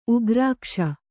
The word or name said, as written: ಉಗ್ರಾಕ್ಷ